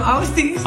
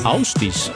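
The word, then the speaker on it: Ásdís: Und der achte von insgesamt 10 Hits am Stück hier auf Krone Hit ist Flashback von einer Sängerin aus Island, die geschrieben wird Asdis, aber ausgesprochen wird Hi, I'm Ausdis. – Ausdis,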